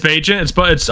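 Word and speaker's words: Bagent: Tyson Badgett. I'm sorry. Is it Badgett or Bajent? I've heard both. It's Bajent.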